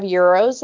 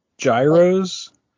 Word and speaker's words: gyros: They have Euros there and edible cookie dough, and it's pretty good in my opinion. – Euros like gyros? – That's not how you pronounce it.